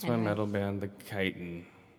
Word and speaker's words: chitin: Also, not to be nitpicky, but it's pronounced chitin. – Oh. – The chitin.